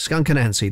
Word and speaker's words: Skunk Anansie: Not everybody can say the name right. Skunk Anansie, that's how you say the band.